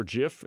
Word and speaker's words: GIF: Somebody says, "graphics interchange format is GIF or JIF," and says, "say with a hard G or don't say it," because it stands for graphics.